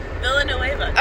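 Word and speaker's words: Villeneuve: How do you say his name? – Villanueva.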